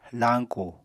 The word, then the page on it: lañku: apatani